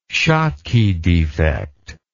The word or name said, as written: schottky defect